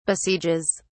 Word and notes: besieges: British English

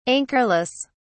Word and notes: anchorless: British English

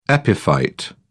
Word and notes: epiphyte: British English

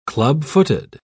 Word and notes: clubfooted: British English

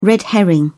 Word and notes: red herring: British English

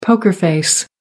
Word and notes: poker face: British English

